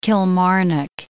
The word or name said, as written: Kilmarnock